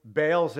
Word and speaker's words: Baal: If you guys remember what happened on Mount Carmel, in order to get Baal's attention, or Baal, depending how you say it, his prophets jumped around like chimps.